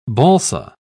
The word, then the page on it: balsa: British English